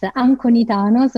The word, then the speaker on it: Anconitano: So it's so hard to pronounce that people call me Veru, V-E-R-U, it's easy. And my surname is even worse than my name. It's Italian, because I'm Italian. It's Anconitano, so it's A-N-C-O-N-I-T-A-N-O.